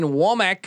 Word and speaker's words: Wommack: I'm talking about the likes of Kane Womack.